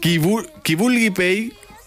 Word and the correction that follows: peix: que es mulli el cul. – Qui vulgui peix, que es mulli el cul.